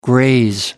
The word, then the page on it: graze: American English